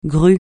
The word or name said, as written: grue